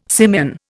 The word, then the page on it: simian: Indian